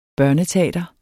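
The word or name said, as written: børneteater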